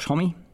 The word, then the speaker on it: Homme: It was produced by Josh Homme of Queens of the Stone Age, or he pronounces it Josh Homme. I think it should be Josh Homme from the French pronunciation, but there you go.